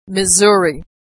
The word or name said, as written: Missouri